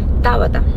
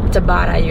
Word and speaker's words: Tabata: Tabata. Actually. – Tabata. – Mm-hmm. – Oh. How do Americans say your name? Tabata? – Tabata, usually, yeah.